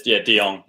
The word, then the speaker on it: De Jonge: Will you pronounce your last name for me, Luke? – It's DeYoung. You said it right.